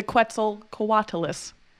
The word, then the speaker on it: Quetzalcoatlus: Is the Quetzalcoatlus real good? – First of all, your pronunciation, real, real good.